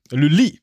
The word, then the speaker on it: Lully: Nein, L-U-L-L-Y schreibt man den. L-U-A, ah, Lully. Lully, wahrscheinlich.